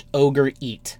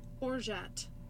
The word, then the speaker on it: Orgeat: I'm pretty sure it's pronounced ogre eat.